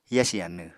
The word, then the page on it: yasi anw: apatani